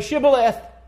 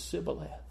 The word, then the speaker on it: Shibboleth: And they said, "Okay, say Shibboleth." And if the man was from Ephraim, he couldn't say it. It was Sibboleth. And when he said Sibboleth and pronounced it wrong, they killed him.